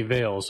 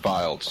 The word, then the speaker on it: vials: Well, you $300 for 20 vials, so that's a lot of... – It's pronounced vials. – Vials. – A veil is what a lady wears over her face at a wedding.